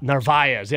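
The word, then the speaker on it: Narváez: Narvaez, yes, the I.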